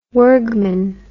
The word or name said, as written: Wirgman